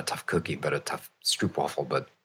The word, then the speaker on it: stroopwafel: I want my stroopwafel. Of course, because it's a Dutch cookie, so I'll pronounce it in the proper Dutch way, and you can make it any waffle you like.